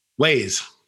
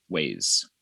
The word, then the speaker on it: Wyze: Wyze. – Wyze.